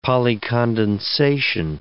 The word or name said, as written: polycondensation